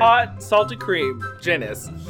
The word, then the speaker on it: Jeni's: Jenny's rhymes with... – penis. – Penis. Does that mean I have to go back? – Genus ice cream. – Genus ice cream. – It's spelled J-E-N-I-S.